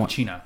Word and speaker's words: Al Pacino: Al Pacino.